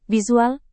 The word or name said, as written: visual